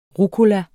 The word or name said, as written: rucola